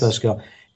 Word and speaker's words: Blaise Pascal: Blaise Pascal. I'm not sure if I'm pronouncing it right. – Oh, yes. – Blaise Pascal.